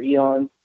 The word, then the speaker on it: Aeon: You can find me on Facebook, Instagram, and Twitter as Trevor Eon. Trevor is spelled T-R-E-V-E-R. Everybody thinks it's Trevor, but my name is actually Trevor. My Eon is spelled A-E-O-N as opposed to E-O-N.